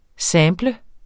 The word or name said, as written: SAMPLE